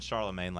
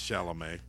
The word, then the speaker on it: Chalamet: How do you say his name? Timothy Chalamet? I keep saying Charlemagne. – I think it's Chalamet.